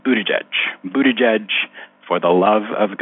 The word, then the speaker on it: Buttigieg: I know Pete sold Buttigieg merch, but that was intended as a cute phonetic guide for people who've never heard his name before. That's not a literal pronunciation.